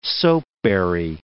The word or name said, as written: soapberry